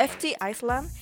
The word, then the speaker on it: FT Island: Para la fan de Fiti Island, así se pronuncia. – FT Island. – Island. – Island. – FT Island. FT Island. – ¿Island? Sí, porque no, la S no se pronuncia.